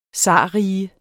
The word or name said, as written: zarrige